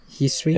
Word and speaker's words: Hisui: Because the region's called Hisui? Hisui? However you pronounce it. – H-I-S-U-I? – Hisui? – Yeah, it'd be like Hisui.